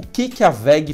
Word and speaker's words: WEG: WEG é como se escreve, W-E-V, mas se lê VEG.